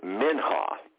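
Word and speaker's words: minha (מנחה): In the Old Testament, the Hebrew word for the word present is the word minha, or it's actually pronounced minha.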